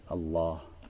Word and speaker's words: Аллах: И потом этот еще удвоенный лям произносится как? Произносится твердо, для возвеличивания, с целью возвеличивания. И получается, что? Поэтому говорят Аллах.